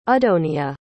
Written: adonia

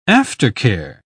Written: aftercare

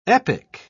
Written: epic

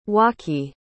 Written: Waki

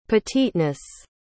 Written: petiteness

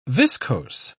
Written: viscose

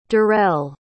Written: derrell

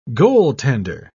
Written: goaltender

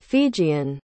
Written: fijian

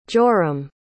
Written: jorim

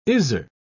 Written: Izar

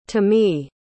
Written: tomie

